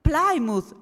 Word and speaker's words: Plymouth: Si scrive Plymouth e si legge? Plymouth, ah, oh, grazie, ok, allora finalmente lo posso pronunciare giusto.